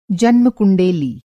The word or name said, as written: जन्मकुंडेली